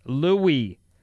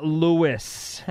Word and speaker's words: Louis: It's pronounced Louis. – Okay. – With the long E, not Louis.